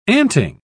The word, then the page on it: anting: British English